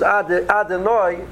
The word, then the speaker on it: Adonai: When we pronounce the Shem's name, so the Mishabura points out that the correct pronunciation should be Milara, so you stress the last syllable. At the same time, a person has to be aware that the middle syllable, written Dalad, it should be pronounced with an O sound, not with an I sound or with an E sound. So a person says Adonai.